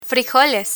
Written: frijoles

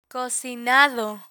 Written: cocinado

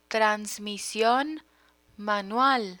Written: transmision manual

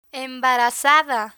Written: embarazada

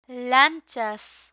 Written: lanchas

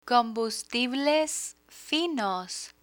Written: combustibles finos

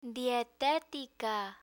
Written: dietetica

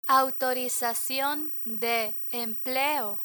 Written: autorizacion de empleo

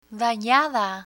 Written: danada

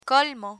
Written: colmo

